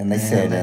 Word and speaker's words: Neisseria: É, não É, Neisseria.